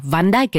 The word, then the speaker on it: Van Dyck: Van Dyck è giusto? – È uno dei tre modi in cui siamo assolutamente autorizzati a pronunciarlo, nel senso che a seconda di dove lui si trova, il suo nome viene pronunciato in modo diverso e noi siamo autorizzati a fare altrettanto. Di base sono tre diverse modalità.